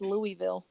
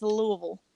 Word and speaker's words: Louisville: Well, I also heard that it's not pronounced Louisville, so. – Yeah, you heard wrong. Joke's on you. – I was shocked. You gotta blubber it out. It's Louisville.